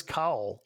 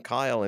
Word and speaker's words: Kyle: It's like he says cowl. – He says cowl. So, Dana, we're not the only ones who can't get pronunciation correct. I mean, even Shatner, who like has probably called him Kyle in many other episodes. Every time in this one, he calls him cowl.